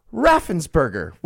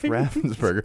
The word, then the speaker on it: Raffensperger: and Raffensperger, which rhymes with all the other burger stuff, so that's confusing too.